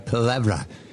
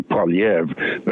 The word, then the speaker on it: Poilievre: And Pierre, it's called technically Poilievre, but in order to facilitate the pronunciation for the English-speaking majority, he calls himself Poilievre.